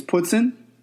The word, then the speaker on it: poutine: Dual too close to the Canadian border for you not to know what poutine, which, by the way, the correct pronunciation is poutine.